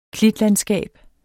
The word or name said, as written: klitlandskab